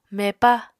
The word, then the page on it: mepa: Apatani